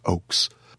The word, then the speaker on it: Ochse: Weston spells his name O-C-H-S-E. It is pronounced like the trees, oaks.